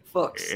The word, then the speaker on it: Fuchs: It rhymes with books. That's how I remember. – I always wondered how you pronounced it. – Fuchs.